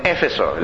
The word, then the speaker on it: Éfeso: En el diccionario le ponen Éfeso, la tilde en la E.